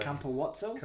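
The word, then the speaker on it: Capuozzo: It's a four-syllable name. – Campowazzo? – Campowazzo.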